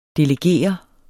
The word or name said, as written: delegeret